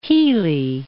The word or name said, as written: Healy